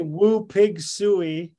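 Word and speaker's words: Woo Pig Sooey: When I say woo pig suey, what emotions come to mind?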